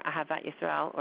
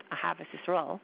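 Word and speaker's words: Ahavat Yisrael (אהבת ישראל): We have love of a fellow Jew, or Ahavat Yisrael, or sometimes pronounced Ahavas Yisrael, is mitzvah number 243.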